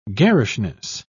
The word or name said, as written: garishness